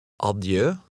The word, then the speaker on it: adieu: In numbers 5, 6, and 7, words of foreign origin, the accent is on the last syllable. Read number 5. – Adieu.